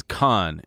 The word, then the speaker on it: Cahn: My last name is Khan, and it's spelled C-A-H-N, and they would say Chan every time.